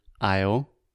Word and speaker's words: aisle: And then we have the word aisle with the exact same pronunciation.